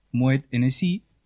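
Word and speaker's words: Moët Hennessy: Moet Hennessy, a ver si lo ha pronunciado bien. – Muy bien.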